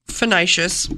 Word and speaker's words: fasciitis: So I've been looking it up, and it's called plantar fasciitis. – Is that how you say it? – Fasciitis.